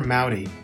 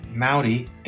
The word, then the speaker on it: Moudy: My name is Taylor Mowdy. I'll spell it T-A-Y-L-O-R, Mowdy, M-O-U-D-Y.